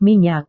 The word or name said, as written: minyak